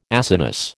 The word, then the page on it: Acinus: American English